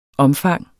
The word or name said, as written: omfang